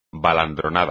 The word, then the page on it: baladronada: espagnol